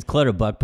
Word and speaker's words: Clutterbuck: It's Clutterbuck, but you called him Buttercup.